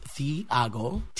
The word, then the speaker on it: Thiago: My name is Thiago, and it's pronounced Thiago, T-H-I-A-G-O, and I'm a singer, songwriter, and performer from Toronto.